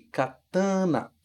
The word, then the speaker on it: katana: Em português a gente fala katana, com esse an aqui, katana. Mas em japonês, em Nihongo, a gente deixa as vogais um pouco menos nasalizadas. Katana, katana, e não katana, ok? Cuidado com esse an, não virar dessa forma.